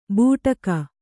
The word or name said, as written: ಬೂಟಕ